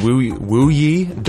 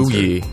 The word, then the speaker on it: Wuyi: It's called, this is an oolong tea called Wuyi Dark Roast. Am I saying that right? – Nice. Well done. Well done, Wuyi. Yeah, W-U-Y-I.